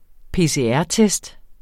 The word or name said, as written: PCR-test